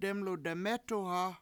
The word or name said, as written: dEmlu de7mEtu7ha